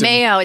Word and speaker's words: Mayo: Is that what it's pronounced? – Mayo. It's Mayo Clinic.